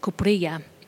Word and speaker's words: Kuprilya: It's spelled lots of ways, but one spelling is K-U-P-R-I-L-Y-A, Kupuria.